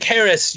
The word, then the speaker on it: Haracz: So I am Chef Mike Haracz. You can follow me by just searching my name, and my last name is spelled just like it sounds H A R A C Z.